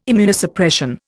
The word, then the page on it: Immunosuppression: Indian